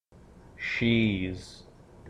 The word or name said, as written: she's